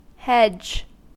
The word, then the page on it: hedge: American English